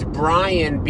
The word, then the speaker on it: Brien: Yeah, people mispronounce my name, too. It's Brian, B-R-I-E-N.